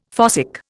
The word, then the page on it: fossick: Indian